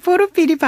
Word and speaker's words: 포르피리: 네, 보루삐리는 예심판사였죠? – 네. 선생님 포르피리 발음이 되게 재밌어요. – 아 러시아식 발음이죠? – 아 포르피리. 그래요? 예. 러시아식 발음이었구나. 저도 진작에 그렇게 할걸.